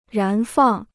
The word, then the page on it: 燃放: rán fàng